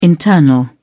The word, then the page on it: internal: British English